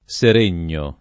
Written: Seregno

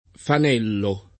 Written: fanello

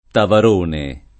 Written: Taverone

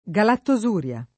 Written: galattosuria

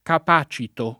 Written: capacitare

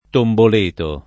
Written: tomboleto

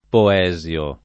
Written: Poesio

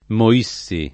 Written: Moissi

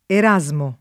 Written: Erasmo

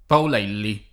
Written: Paolelli

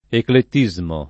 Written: eclettismo

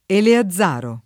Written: Eleazaro

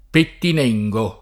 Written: Pettinengo

